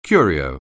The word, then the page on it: curio: anglais